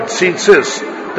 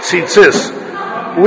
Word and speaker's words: tzitzis: That's the right way to pronounce the word, tzitzis. The first one has a yud, so it's e, and the second one doesn't have a yud, so it's it, tzitzis.